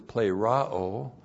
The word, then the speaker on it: plerao: The universal indwelling of God the Holy Spirit, the filling of the Spirit, which means to be empowered, the word is plerao.